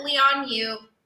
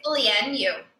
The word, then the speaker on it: Lian Yu: They call it Leon Yu. Micah, you're not gonna believe this. That's not the correct way to pronounce it. – Oh my gosh. – It's Lian Yu.